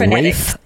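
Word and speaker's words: Ralph: Is it Ray Fiennes? Is that how you pronounce his last name? – Ray Fiennes. – Man, everyone pronounces his name differently. I never know who to trust. – There's a PH at the end. – All right, so Rafe Fiennes.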